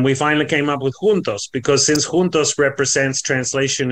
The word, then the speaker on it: juntos: They're totally different languages, but this particular word is spelt the same, although it's pronounced quite different. So in Spanish it's juntos and in Portuguese it's juntos, but it still means together.